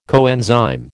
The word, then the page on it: coenzyme: American English